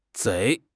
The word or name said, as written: 贼